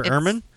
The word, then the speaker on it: ermine: Is that how it's pronounced? Ermine? – Ermine.